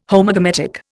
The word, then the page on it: Homogametic: Indian